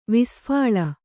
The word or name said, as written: ವಿಸ್ಫಾಳ